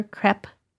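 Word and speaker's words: crêpe: Crepe maker? Crepe? Crepe, I think, is the right way to say it. – And I've been saying it wrong a long time.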